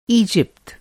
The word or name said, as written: ईजिप्ट